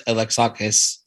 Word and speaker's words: Alexakis: So when, again, back to the importance of a producer, you said his name, Art Alexakis. Mm-hmm. I've never pronounced his name that way. Art Alexakis. I've mispronouncing it for a long time.